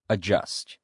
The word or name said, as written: adjust